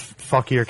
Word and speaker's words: Fauquier: That's Fauquier County. – Fauquier.